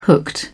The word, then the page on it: hooked: British English